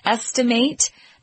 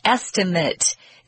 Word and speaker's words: estimate: Notice here that it's pronounced estimate. That's the verb form. If you use the noun form, it's estimate. It's a completely different pronunciation, so be careful. Estimate with a long A sound for the verb, and estimate with sort of a short I sound, estimate, for the noun.